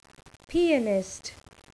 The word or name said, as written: pianist